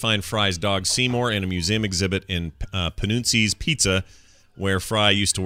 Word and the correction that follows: Panucci: Am I saying that right? I probably remember. – Panucci. – Is it Panucci? Yeah, Panucci's Pizza.